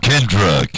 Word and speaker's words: Kendra: Kendra. – K-E-N-D-R-A.